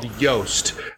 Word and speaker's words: Yoast: It's called Yoast. Y-O-A-S-T is how you spell it.